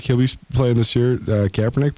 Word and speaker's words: Kaepernick: I mean, it would be great to see Colin Kaepernick. Can I say his name right? – Yes, you almost got it. Kaepernick, yeah.